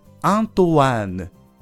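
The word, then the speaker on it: Antoine: ただし、アントワーヌは ENE で終わっていますが、男性の名前です。これは発音も in ではなく one ですよね。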